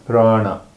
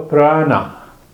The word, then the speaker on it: prana: Yes, energy is there, and the word that you spoke is prana. Come on, Craig. So, prana. P-R-A-N-A is a Sanskrit word. Mm-hmm. And it has 2 syllables. Prana. Prana.